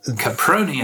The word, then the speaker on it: Caproni: And I noticed something on the podcast index, a platform I've not heard of called Caprion FM. – Caproni FM. It's like, thank you. I tell you, my pronunciation this week, I've learned so much. – Yeah, it's like pasta, except it's not pasta because I think it's Spanish.